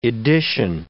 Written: edition

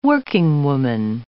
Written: workingwoman

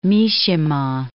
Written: Mishima

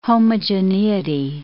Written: homogeneity

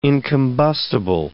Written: incombustible

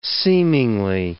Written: seemingly

